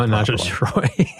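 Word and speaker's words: ménage à trois: Menage a Trois, or Menage a Troy, I guess I should pronounce. It's it Menage a Troy.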